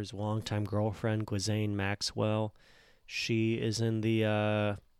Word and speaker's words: Ghislaine: Google's got audio correct for me. Ghislaine Maxwell, yeah, Ghislaine, Ghislaine Maxwell.